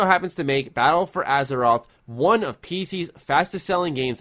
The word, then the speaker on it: Azeroth: The latest World of Warcraft expansion, Battle for, and I apologize if I mispronounce this, Carrick, if you know how to pronounce it, please correct me, Battle for Azeroth? – Azeroth. – I'm going to go with Azeroth. – That's pretty close, yep.